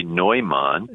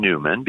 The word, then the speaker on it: Neumann: You know, the Bishop of Philadelphia in the early 19th century, and of course, if you see his name and you know German, you would say Neumann, yeah. – Right? But he himself asked for people to pronounce his name Newman because he wanted to conform to the American speech patterns.